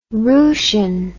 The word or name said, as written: roosian